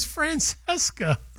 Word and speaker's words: Francesca: Francesca. Did I say that right? Francesca. – No, it's Francesca.